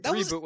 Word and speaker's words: ReBoot: Reboot with an emphasis on the re.